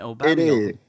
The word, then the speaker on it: Aubameyang: well, that is how he pronounces, isn't it? Aubameyang.